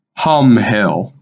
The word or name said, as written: Humm Hill